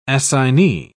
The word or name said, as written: assignee